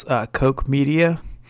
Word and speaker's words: Koch Media: Eastern Time, I mispronounced this name on one of our podcasts a while ago. It's Koch Media. I believe they call it Koch Media.